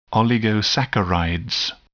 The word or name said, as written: oligosaccharide